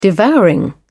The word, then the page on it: devouring: British English